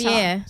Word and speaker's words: Javier: I never say his name right. – No, Javier. You say it better. Javier. Yeah, you say it better. You've got the flair.